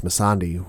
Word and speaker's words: Missandei: Well, we've been pronouncing the name wrong the entire time, so apologies. It's Missandei who's been missing.